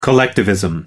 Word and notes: collectivism: American English

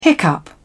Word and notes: hiccup: British English

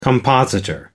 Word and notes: compositor: American English

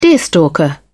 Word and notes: deerstalker: British English

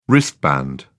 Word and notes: wristband: British English

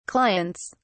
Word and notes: clients: British English

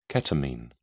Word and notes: ketamine: British English

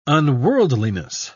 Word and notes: unworldliness: British English

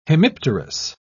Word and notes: hemipterous: British English

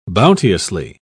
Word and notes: bounteously: British English

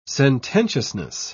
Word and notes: sententiousness: British English

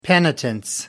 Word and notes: penitence: British English

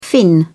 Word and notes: finn: British English

